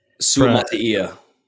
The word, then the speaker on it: Suamataia: Or I'm going to butcher this name, but Kingsley Salmatia. Is that close enough? No. Give me the correct pronunciation. – Suamataia. Kingsley Suamataia.